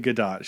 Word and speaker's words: Gadot: It's actually Gadot. She's actually pronounced it Gal Gadot.